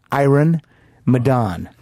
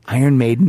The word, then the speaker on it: Iron Maiden: Iron Maiden. – Wrong. – Iron Maiden.